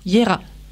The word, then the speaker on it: Jera: Yera. – Oui, voilà, je ne l'ai pas encore dit tout à l'heure. Ça s'écrit J-E-R-A.